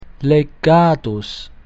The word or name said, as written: legatus